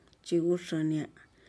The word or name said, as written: chiguxunya'ah